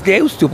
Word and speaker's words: Deusto: Es Deusto, porque se escribe con U final. – Deusto.